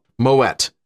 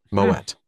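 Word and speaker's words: Moët: Oh, Moet. The reason it's not pronounced the French way, I Googled this, I was trying to figure out.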